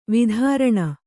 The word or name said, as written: ವೈತರಣಿ